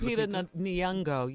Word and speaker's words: Lupita Nyong'o: Lupita. Am I saying that right? – Lupita Nyong'o, yes. Yeah.